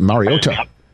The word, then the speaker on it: Mariota: how is your name pronounced, and he said it. And when you heard him introduce himself in the offense, Mariota.